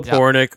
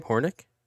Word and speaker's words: Hronek: So Filip Hronek, is that how you say his name? Hronek? – Hronek. – Hronek. The silent H? Okay. – It's like Hronek. Hronek. – Hronek. Okay. – Something like that.